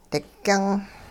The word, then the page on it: digyung: hupa